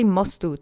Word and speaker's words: mostu: So let's say mostu together three times. Accent is on the first syllable. Mostu. Mostu. Mostu.